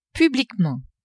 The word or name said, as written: publiquement